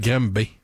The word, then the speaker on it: Granby: Est-ce que tu peux, s'il te plaît, nous épeler Graham B? – G-R-A-N-B-Y. – D'ailleurs, y a-tu quelqu'un qui veut m'expliquer pourquoi on prononce pas ça Graham B? – C'est parce que ça s'appelle Graham B.